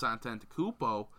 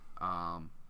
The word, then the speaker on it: Antetokounmpo: You know, you get Sean Sweeney, a guy that has worked with Giannis Antetokounmpo. I know I butchered that. I never will get that one last name now. – Antetokounmpo? – You get Sidney Lowe.